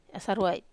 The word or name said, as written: asaruai